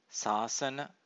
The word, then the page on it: sasana: Pali